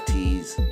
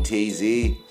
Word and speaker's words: TZ: But like on IG, people don't pronounce it as Tease. They pronounce it TZ. – TZ Mac. – So everybody calls me TZ.